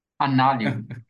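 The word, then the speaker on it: Anālayo: Eu não sei também se eu estou falando errado. Analaio. A... Como é mesmo? – Análio.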